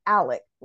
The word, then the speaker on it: Alex: He pronounces it Alec, like Alec Baldwin.